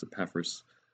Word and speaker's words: Epaphras: Well, a guy called Epaphras, or Epaphras, I think the correct pronunciation is Epaphras, but the other one probably wouldn't be that far off the mark.